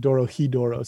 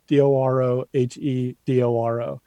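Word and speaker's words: Dorohedoro: Doro El Doro? – Doro Edoro, or I think they pronounce it Doro Hidoro. So Doro Hedoro.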